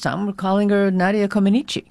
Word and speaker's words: Comăneci: Which apparently most of us have been pronouncing wrong for the past 50 years. – Uh-oh, tell me what is the right way. – Well, no, you did it correctly. – Oh. – It's Nadia Comăneci.